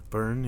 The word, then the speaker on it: Byrne: I think it's Byrne, B-Y-R-N-E. – Byrne, yeah. – It's Byrne, right? Like David Byrne? – Yeah.